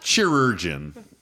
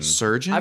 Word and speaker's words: Chirurgeon: Chirurgeon. – It's Chirrutigarn. – Chirurgeon. – Chirurgeon. – Surgeon. – I've got no. It's a ch. – I got Grenzo that Chirurgeon.